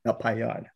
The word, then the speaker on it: Napagna: Napagna. Hope I said it correctly. – Napayon. – So the Y is active. Napayon.